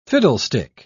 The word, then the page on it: fiddlestick: British English